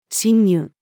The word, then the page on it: 侵入: female